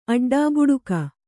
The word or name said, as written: ಅಡ್ಡೆಬಡಕ